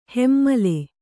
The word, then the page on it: ಹೆಮ್ಮಲೆ: hemmale